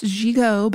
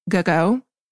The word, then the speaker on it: gigot: And yes, it is French and pronounced gigot, but I actually prefer the alliteration gogo because, especially when you say it in reference to American Duchess's Go Go Girl Gang because of the alliteration.